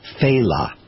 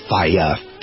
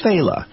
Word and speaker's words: Failla: His name is pronounced Faila. Faila. It's spelled F-A-I-L-L-A. So people go Faya, Faila. You know, but it's Faila.